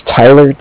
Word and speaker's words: Tyler: Yeah, my name's Tyler, T-Y-L-E-R. – Okay. – And I'm calling with Cardiff. It's spelled C-A-R-D-I-F-F, as in Frank.